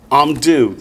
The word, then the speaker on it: Amdu: Amdu. That is correct. Remember, the first two letters make a syllable, and the last letter gets an u added to the end.